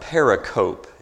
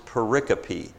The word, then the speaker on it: pericope: It looks like pericope. It's actually pronounced pericope.